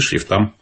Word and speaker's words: шрифтам: А Ограничения есть и по шрифтам. Именно так ставится ударение, правильно? Шрифты, шрифтам. Их тоже должно быть не более трех.